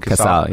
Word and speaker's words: Casal: So now you and throughout the years, you and Raphael, how do you say his last name? – Casal, yeah, yeah.